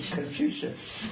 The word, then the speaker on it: Confucius: Confucius.